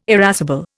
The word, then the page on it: irascible: Indian